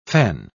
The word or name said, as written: fen